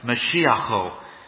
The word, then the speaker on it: Mashiach: Now, incidentally, the Hebrew word here in verse 2 that's used for the Lord's anointed, that word there is pronounced in Hebrew as Mashiacho, Mashiach. It's where we get the word Messiah. You might even be able to hear it in there, Mashiach, Messiah in English.